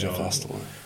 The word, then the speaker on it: Jeff Astle: Är det så man uttalar det? – Jeff Astle.